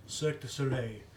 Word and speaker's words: Cirque du Soleil: Do you get Circus de Soleil, ever call it? – we actually do it in... – Did I even say that right? – Cirque du Soleil.